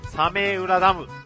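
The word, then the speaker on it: 早明浦ダム: ますので、まあ、それでも茹で続けるんですけど、そのね、サメウラダムがですね、枯渇しないように台風が来てくれるとすごくラッキーだったりするんです。はい。まあね、一体サメウラダムとはどんなものかっていうのはですね、画像で検索していただければわかります。はい。サメウラダム。早い明るい浦のダムと書いてサメウラダムっていうのがあります。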